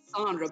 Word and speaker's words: Cassandra: Is it Cassandra or Cassandra? Let me say that right. – It is right. – Cassandra, but I will literally never notice or correct you.